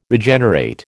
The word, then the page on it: Regenerate: American English